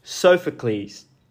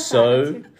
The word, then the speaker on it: Sophocles: Now, that quote was written by Sophocles.